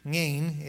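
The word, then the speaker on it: Ayin: So this section of Ngain is sort of how you say it. There's a ng on front of that, sort of clear your throat.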